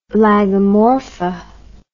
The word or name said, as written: Lagomorpha